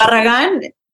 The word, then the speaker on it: Barragan: Barragan. Pero uh, I think Berrigan is like the, this, the American, people.